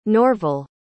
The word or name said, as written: norvel